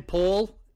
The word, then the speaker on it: Paola: I think it's Paola, P-A-O-L-A, in Guatemala. – Oh, well, in Spanish, people with that name, they call each other Paola. – Oh, so it is Paola. Yeah. Okay. – And some people say Paul, like almost like a boy's name, Paul.